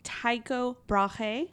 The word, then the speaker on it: Tycho Brahe: His name is Tycho Brahe.